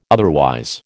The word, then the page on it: otherwise: American English